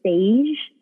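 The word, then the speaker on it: Saje: I don't know if I'm pronouncing the brand correctly, but it's S-A-J-E, Sage is what I call it, or Sage.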